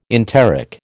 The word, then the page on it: enteric: American English